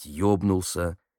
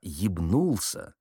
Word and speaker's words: ебнулся: Раз он прикрикнул на какого-то зэка: «Ты что, ебнулся?» Именно так поставив ударение. Зэк реагировал основательно. «Гражданин сержант, вы не правы. Можно сказать «ебнулся», «ебанулся» и «наебнулся». А «ебнулся» — такого слова в русском литературном языке, уж извините, нет.